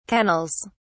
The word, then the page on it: kennels: British English